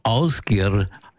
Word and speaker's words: Ásgeir: Ásgeir wird er ausgesprochen, geschrieben wird er Ásgeir.